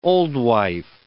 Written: old wife

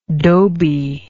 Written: doby

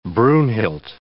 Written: Brunhild

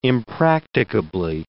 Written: impracticably

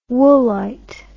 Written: woolite

